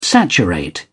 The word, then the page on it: saturate: British English